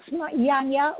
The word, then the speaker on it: Janya: And yes, I will clarify my name for sure, because pronounce Janya with a Y at the beginning, not a J.